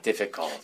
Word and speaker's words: difficult: You know, I hear a lot of people try to say this word, but if your pronunciation is not correct, it kind of sounds like this. Difficult. Difficult. That's not correct. You really need a strong F.